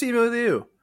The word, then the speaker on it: Simu Liu: No, it's Simu Liu.